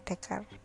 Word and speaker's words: Descartes: Yang benar adalah Descartes atau bahasa Latinnya tulisannya Descartes ya, tapi bacanya Descartes.